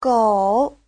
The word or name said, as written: gou3